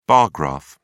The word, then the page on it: bar graph: British English